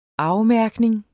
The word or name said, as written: afmærkning